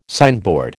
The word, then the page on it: signboard: American English